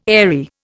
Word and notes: airy: Indian

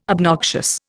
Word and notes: obnoxious: Indian

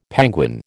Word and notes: penguin: American English

